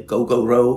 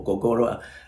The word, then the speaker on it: Gogoro: And then also another very famous brand, we have another scooter, electric scooter, is called Gogoro. It's spelled G-O-G-O-R-O, so you can say Gogoro or Gogoro. People pronounce it differently.